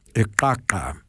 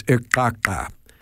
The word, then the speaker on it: iqaqa: The word for skunk is spelled I-Q-A-Q-A and pronounced Ikaka. It's one of the click languages. So Say that again. I can hear that click. – Right. It is Ikaka.